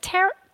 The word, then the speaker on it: tarot: Is it Tarot? It's tarot. I never know how to say it.